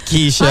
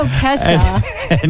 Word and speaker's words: Kesha: I mispronounced it, Keisha. – I know, Kesha.